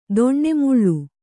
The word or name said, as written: ದಾನಮೂಲ